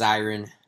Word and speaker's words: Zirin: I just got to say it's Zirin.